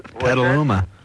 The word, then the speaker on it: Petaluma: Yeah, well, I'm going to see you guys in Petaluma. I can't wait for that. – How do you pronounce that? – Petaluma.